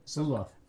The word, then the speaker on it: Luloff: Yeah, Luloff.